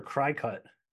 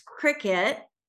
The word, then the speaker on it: Cricut: C-R-I-C-U-T. – Either cricket or cry-cut. – Yeah, exactly. And it's cricket. I, for years, pronounced it cry-cut until I started working with the queen of cricket and realized, oh, it's the same word.